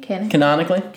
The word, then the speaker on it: canonically: How do you say it? Canically? – Canonically? – Canonically? Really? Yeah. That seems wrong, but... – It's part of the canon.